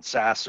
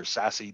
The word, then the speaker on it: SASE: For us, the real opportunity on the horizon is around SaaS or SASE, depending on how you want to pronounce it.